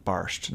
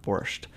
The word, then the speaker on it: barszcz: They pronounce it barszcz, not borscht, in Poland.